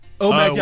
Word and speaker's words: OMAD: Is it OMAD? – OMAD, yeah, one meal a day.